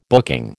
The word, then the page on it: booking: American English